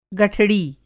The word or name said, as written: गठड़ी